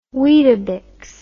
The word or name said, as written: weetabix